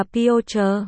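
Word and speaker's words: Piotr (Пётр): Tiếng Nga đọc là Piotr.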